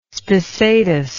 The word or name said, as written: spissatus